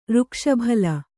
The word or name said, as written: ಋಕ್ಷಭಲ್ಲ